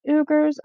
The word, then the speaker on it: Uyghur: And also, when discussing the Chinese Communist Party's treatment of a large group of people in their country, spelled U-Y-G-H-U-R-S, I have heard it pronounced Uyghurs. I have also heard it pronounced Uyghur. Whatever the proper pronunciation is, you know, I guess it depends on who you're listening to. But when I looked it up after he mentioned it, it does seem like most say Uyghur.